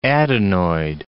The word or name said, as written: adenoid